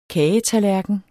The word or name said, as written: kagetallerken